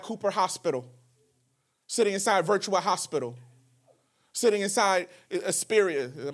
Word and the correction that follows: Inspira: Am I saying it right? Inspira. Sitting inside of one of these hospitals because of a tragedy that happened on Friday, but you can't get there till Sunday.